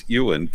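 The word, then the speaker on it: Iwerne: Well, evidently in England, they pronounce the I as a U, and they don't pronounce the W, so it's Ewan camps.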